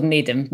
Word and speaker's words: Nedham: Well, he's sometimes called Nedham, sometimes called Needham. Marchmont Needham, I tend to call him, but yeah, either.